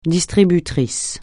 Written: distributrice

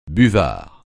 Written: buvard

